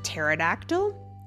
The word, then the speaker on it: pterodactyl: Like the word pterodactyl also has a P in front of it, but you don't pronounce it.